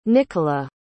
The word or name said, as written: Nicola